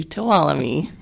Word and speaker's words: Tuolumne: And when we try to tell people how to say it, we usually say, follow me to Tuolumne.